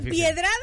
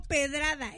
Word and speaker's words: pedrada: ¿Es piedrada o pedrada? Es pedrada. Hay una muy, piedrada, muy, también muy común, la de viniste.